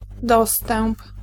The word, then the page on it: approche: France; /a.pʁɔʃ/